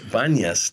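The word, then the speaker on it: Banias: But everybody else called it panyas. And in the Arabic language, they can't pronounce the P, so they changed it to a B. So it's banyas today.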